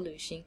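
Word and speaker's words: lǚxíng: So it's L, V, or U with the umlaut on top of it, and then X-I-N-G. Luxing.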